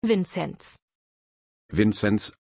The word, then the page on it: vincenz: German